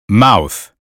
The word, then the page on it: mouth: American English